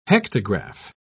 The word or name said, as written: hectograph